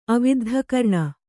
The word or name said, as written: ಅವಿದ್ಧಕರ್ಣ